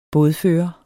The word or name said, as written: bådfører